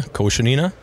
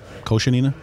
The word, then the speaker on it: Koshenina: I said that right? – Yeah, Koshenina. – Koshenina.